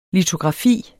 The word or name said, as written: litografi